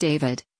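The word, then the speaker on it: d4vd: David, pronounced David, gained popularity among young fans for his blend of indie rock, R&B and lo-fi pop.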